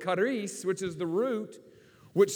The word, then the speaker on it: charis: It's pronounced charis, and it means grace.